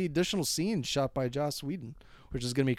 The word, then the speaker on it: Joss Whedon: And they brought in Josh Whedon. Or, I'm sorry, I got to pronounce that. Make sure I really enunciate that. Joss Whedon.